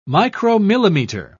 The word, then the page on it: micromillimeter: British English